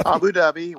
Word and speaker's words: Abu Dhabi: Abu Dhabi. – Abu Dhabi.